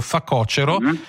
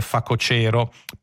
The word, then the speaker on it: facocero: Secondo la Crusca propongono come corretta la pronuncia con l'accento sulla penultima, accettando facocero, ma dicendo che quella più giusta dovrebbe essere facocero.